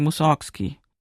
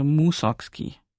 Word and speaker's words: Mussorgski: Die Deutschen sagen Mussorgski. Die gepflegten Russen sagen auch Mussorgski. Er selber hat Wert darauf gelegt, es ist verbrieft, dass sein Name auf der ersten Silbe betont wird, also Mussorgski.